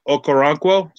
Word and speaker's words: Okoronkwo: But they go with Agbanye Okoronkwo.